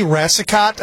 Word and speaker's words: Racicot: Maine resident Jenny Racicot, I believe is how you pronounce her name, R-A-C-I-C-O-T.